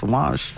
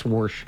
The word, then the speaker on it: wash: And I used to look at them and go, there's no R in wash. It's wash. – I like anyway. It. – They always thought it was me saying it wrong because I used to drop my R's coming from New England. – That's funny. – So I used to get grief for calling it wash. It's Warsh.